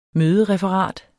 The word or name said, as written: mødereferat